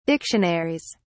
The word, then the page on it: dictionaries: British English